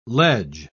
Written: ledge

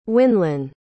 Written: Winlyn

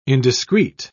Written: indiscreet

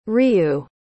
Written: ryuu

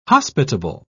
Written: hospitable

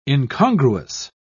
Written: incongruous

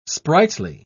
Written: sprightly

